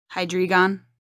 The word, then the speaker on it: Hydreigon: It's H-Y-D-R-E-I-G-O-N. Hydreigon? – Hydreigon. Hydreigon. E-I is like my, like chaotically. So like Hydreigon. And then the G-H at the end. Yeah, it's like Hydra. It's just like saying my name, except for put Hyde at the beginning of it and on at the end of it.